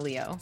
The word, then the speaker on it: Leo: Twitter at Miss Oriana Leo. Instagram and everything else is at Oriana Leo. – So spell that. – O-R-I-A-N-A. L-E-O literally means golden lioness in Italian.